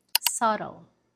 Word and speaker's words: subtle: Subtle. Subtle. The B is silent.